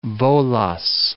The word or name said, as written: Volos